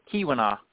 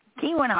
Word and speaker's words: Keweenaw: And by the end of this episode, we're going to get you to pronounce it Keweenaw. – Keweenaw.